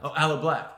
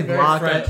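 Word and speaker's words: Aloe Blacc: I say his name weirdly. I say Blanc. I make him sound French. I don't know why. – Blanc. Aloe Black. – I know, I say block, but Aloe Black.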